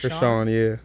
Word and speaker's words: Chrisean: That's Chrisean, how you say it? – Chrisean, yeah.